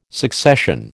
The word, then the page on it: succession: American English